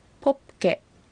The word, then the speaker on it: ポッケ: ポッケ、小さい間にプが入ってますので唇は閉じて発音してくださいね。